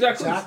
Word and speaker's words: Jacquees: I think it's Jacquees. – Jacquees. – It's Jacquees. – Jacquees? – Yeah. – No, Jacquees is with Q-U-I-S, right? – I don't know. He pronounces it Jacquees.